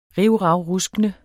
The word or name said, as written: rivravruskende